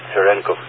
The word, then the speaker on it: Cherenkov: When you mentioned Cherenkov radiation, that's how you pronounce it, Cherenkov radiation, known as Vilov-Cherenkov radiation.